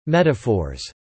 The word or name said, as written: Metaphors